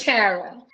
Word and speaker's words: Tara: Is it Tara or Tara? – It's Tara.